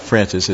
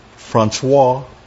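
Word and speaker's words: François: It says, his name is Jean Francis, actually it's not Francis, it's Francois, got the French pronunciation here, Modelet, and I'm a Christian leader in a local non-denominational evangelical church in Moratius.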